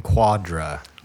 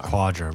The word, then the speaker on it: Quadra: Their newest album, Quadra. – Quadra.